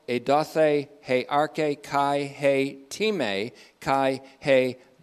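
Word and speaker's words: Time (τιμή): Time, T-I-M-E, long E, means honor.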